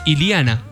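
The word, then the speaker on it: Illana: Hablando de presentaciones de técnicos, hoy también Deportivo Cuenca presentó a Andrés Iliana, así se pronuncia el apellido. – Iliana, nos decía hoy el profe, si le preguntaban. – Iliana. – Dijo Iliana. La doble L se pronuncia como L-I.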